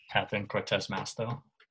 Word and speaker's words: Catherine Cortez Masto: Catherine Cortez Maestro. Did I say that right? – Catherine Cortez Masto. I think it's. – Yeah, there we go.